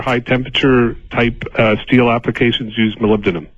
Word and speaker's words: molybdenum: Well, for all those that can't pronounce it, you short it to moly. – Moly. – But it's molybdenum.